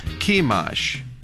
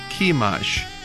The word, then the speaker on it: Chemosh: Sihon, S-I-H-O-N, Sihon 이렇게 발음해 주시고 그모스는 C-H-E-M-O-S-H입니다. 그래서 Chemosh, 한번 더요. Chemosh, 그모스를 Chemosh 이렇게 연습해 주시기 바랍니다.